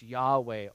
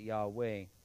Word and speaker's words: Yahweh: It's Yahweh, or again, if in Hebrew it'd be Ayah, we just pronounce it Yahweh.